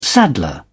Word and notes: saddler: British English

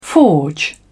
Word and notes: forge: British English

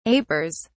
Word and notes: apers: British English

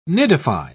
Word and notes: nidify: British English

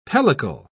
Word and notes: pellicle: British English

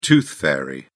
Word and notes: tooth fairy: British English

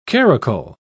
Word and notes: caracul: British English

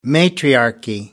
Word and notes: matriarchy: American English

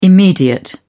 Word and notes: immediate: British English

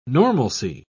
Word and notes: normalcy: British English

